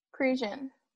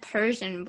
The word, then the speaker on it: Prussian: It's pronounced Prussian blue? – Prussian. – Prussian blue? Oh, okay. I thought you said Persian blue in the beginning.